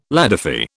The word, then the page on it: ladyfy: British English